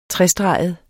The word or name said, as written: trestreget